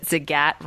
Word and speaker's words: Zagat: Side point, I'm really happy to hear you say Zagat, because I learned on an episode of Will and Grace that Zagat rhymes with the cat.